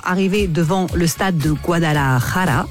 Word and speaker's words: Guadalajara: On dit Guadalajara. – Non. – Parce que la rota en espagnol se prononce R et le R R. Guadalajara. Vas-y. – Guadalajara.